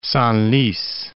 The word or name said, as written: Senlis